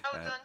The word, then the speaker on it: Aodeon: Merci donc de nous accorder un peu de temps à Radio Campus Tours, bassiste au sein de Aodeon. Je ne sais pas si on le prononce comme ça. Oui, si. – Aodeon, c'est bien.